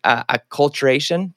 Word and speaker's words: acculturation: and I'm going to say this right, acculturation. Did I say that right? – Yes.